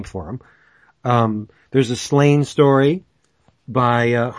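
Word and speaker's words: Sláine: Is that the way you pronounce it? Slain? – I always have. Why would you think? – Yeah, it was? – It makes sense. Slain.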